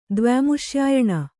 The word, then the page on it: ದ್ವ್ಯಾಮುಷ್ಯಾಯಣ: dvyāmuṣyāyaṇa